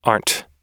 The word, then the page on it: aren't: American English